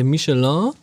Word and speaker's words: Michelin: Der Michelin, habe ich Michelin richtig ausgesprochen? Hast du.